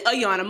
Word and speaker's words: Ayanna: It's actually Ayana. Most times you say Ayana.